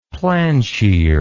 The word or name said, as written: plansheer